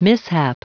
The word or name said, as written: mishap